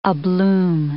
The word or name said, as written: abloom